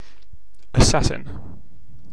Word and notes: assassin: British English

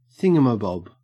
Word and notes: thingamabob: Australian English

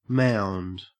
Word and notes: mound: Australian English

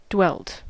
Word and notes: dwelt: American English

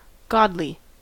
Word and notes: godly: American English

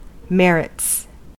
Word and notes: merits: American English

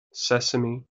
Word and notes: sesame: American English

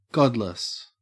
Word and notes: godless: Australian English

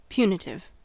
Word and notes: punitive: American English

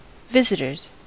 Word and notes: visitors: American English

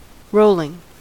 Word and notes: rolling: American English

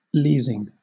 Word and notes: leasing: British English

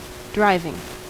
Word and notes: driving: American English

